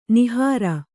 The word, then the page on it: ನಿಹಾರ: nihāra